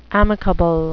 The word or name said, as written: rapscallion